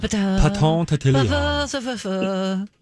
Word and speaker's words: Coton de Tulear: Coton de Tulear.